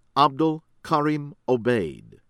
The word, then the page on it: Abdul Karim Obeid: American English